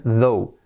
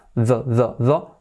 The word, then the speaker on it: though: Pero la pronunciación es though. Lo mismo la th, en este caso es sonora, though.